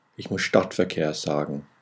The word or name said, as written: Stadtverkehr